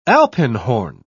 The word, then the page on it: alpenhorn: British English